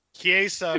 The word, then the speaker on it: Chiesa: Is it Chiesa or Chiesa? How do you say it? – Chiesa, according to the pronunciation.